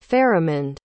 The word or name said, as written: faramund